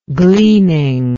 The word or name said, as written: gleaning